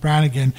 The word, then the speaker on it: Brangan: even though that's the way it's generally pronounced, Brannigan, even though the spelling on the defendant's name is Brangan, B-R-A-N-G-A-N, and I spell it to you in case you want to look it up and read the decision yourself.